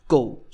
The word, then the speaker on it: god: And the word goal with a soft D usually is pronounced goal. So you can actually drop the D.